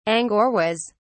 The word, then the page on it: angoras: British English